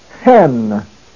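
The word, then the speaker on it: hen (ἕν): But with a rough breathing above the epsilon, it is pronounced hen.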